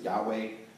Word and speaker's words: Yahweh: And the Hebrew letters for Lord are YHWH. We pronounce it Yahweh.